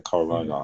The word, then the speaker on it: Carl Reiner: Is it Carl Reiner or Carl Reiner? – Probably Reiner. – Yeah, sounds like Reiner. Yeah, Carl Reiner.